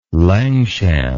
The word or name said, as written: langshan